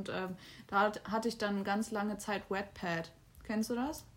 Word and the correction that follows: Wattpad: Also, also W-A-T-T, also What, What Wattpad, Wattpad ja, spricht man nee, so keine aus.